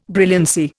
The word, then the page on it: brilliancy: Indian